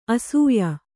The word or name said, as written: ಆಶಯ